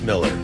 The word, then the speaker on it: Mueller: I know it's got a U in it, but it's pronounced Miller.